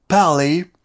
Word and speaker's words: Parler: En fait, il faut prononcer ça Parler. En anglais et c'est un mot anglais, c'est un mot qui vient de l'époque des pirates et des corsaires.